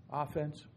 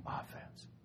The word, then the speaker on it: offense: Offense. Pronounced it wrong. Offense.